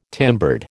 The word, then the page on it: timbered: American English